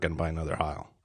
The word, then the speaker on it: Heil: Um, and I, and the old one was a Heil. Is that how you pronounce it? Yeah.